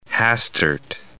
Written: Hastert

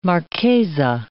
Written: marchesa

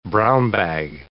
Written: brown-bag

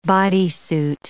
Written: bodysuit

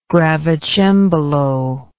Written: gravicembalo

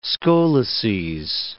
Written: scoleces

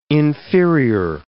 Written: inferior

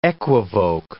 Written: equivoke

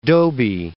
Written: Dobie

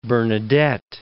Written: Bernadette of Lourdes

